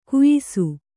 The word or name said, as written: ಘಯ್ಸ